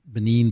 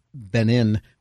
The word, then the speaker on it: Benin: And so with that, people in Benin, I guess I said Benin before, I mispronounced it, Benin, will build electrical infrastructure? – That's exactly right.